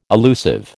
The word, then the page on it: allusive: American English